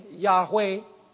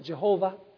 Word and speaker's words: Yahweh: Speaking about the Lord, L-O-R-D, all capital letters, which is the covenant name, Yahweh, or as we mispronounce it, Jehovah.